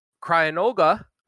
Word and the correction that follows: Cryogonal: How did you say D? – I don't know how to say it. – Cryogonal, because Cryogonal. It's like a, he's got the shape. – You flipped your G and your N. – Cryogonal. There we go.